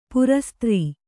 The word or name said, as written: ಪ್ರಸ್ತರ